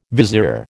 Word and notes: vizier: American English

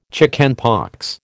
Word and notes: chickenpox: American English

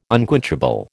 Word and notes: unquenchable: American English

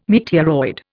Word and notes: meteoroid: Indian

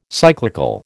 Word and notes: cyclical: American English

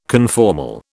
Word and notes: conformal: British English

